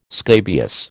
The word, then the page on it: scabious: American English